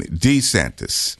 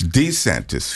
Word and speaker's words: DeSantis: And by the way, has anybody noticed that Mr. DeSantis is now pronouncing his name DeSantis. It's DeSantis, folks. It's not DeSantis. It's DeSantis. And I think that he has no choice.